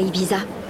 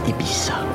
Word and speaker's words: Ibiza: Sa femme est à Ibiza. en laissant ses diamants à la maison, craignant qu'on les lui vole. – Ça se prononce Ibiza.